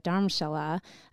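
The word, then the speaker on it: Dharamshala: I went to India years ago I went to a place called Dom. I always say it wrong, but Dharmshala up by Tibet, mm-hmm in northern India.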